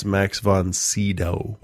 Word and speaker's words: Max von Sydow: And I looked his name up to pronounce it properly. Yeah. And it's Max von Sydow.